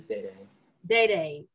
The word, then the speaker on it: Dada: Yes, and girl, I'll be correcting you when you say it, but it's Day-Day. – Day-Day. Okay, you can correct me. – I know it's spelled like Dada.